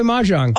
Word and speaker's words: mahjong: I think what I was kind of bringing it to was I've mentioned often on this show how, I don't know how to pronounce it. Mahjong? – Mahjong.